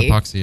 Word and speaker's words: Epoxy: And that would be Kid at the Corner, Epoxy? I don't say that right. – Epoxy. – Yeah. Epoxy. – I think it's Epoxy, yeah.